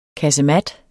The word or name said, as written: KASEMATS